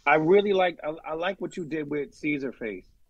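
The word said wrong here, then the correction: Czarface: Oh, the Czar Face. – Czar Face, yes. – Yeah, Czar. – I always say that wrong.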